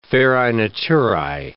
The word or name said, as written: ferae naturae